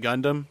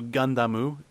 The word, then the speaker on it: Gundam: And, you can pronounce it Gundam just fine. Although Gundamu, I think, is the correct way to pronounce it.